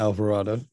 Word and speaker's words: Alvarado: Yeah, Alvarado.